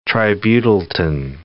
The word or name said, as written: tributyltin